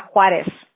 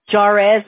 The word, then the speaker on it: Juárez: T-L-A-H-U-A-T-L. Es como mi apellido de soltera era Juárez. Oh, my gosh. If you would hear my teachers cómo pronunciaban mi nombre, Juárez decían, porque como la J es... como no es silent, right? No yeah. pronuncias como J y ellos siempre, ay Dios mío. Juárez me decían así.